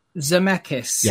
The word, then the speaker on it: Zemeckis: Can you say his name? And be very careful how you say this. – Zemeckis. – Yes, that's correct.